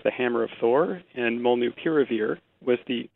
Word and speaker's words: molnupiravir: Do we call it the Merck pill, or do we actually call it by its name, which I believe is pronounced Molnupiravir? – What do you say? – So I use the work term molnupiravir, and I'll tell you why. Because the actual name is derived from Mjolnir, the Thor's hammer.